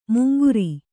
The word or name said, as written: ಮಂಗರೆ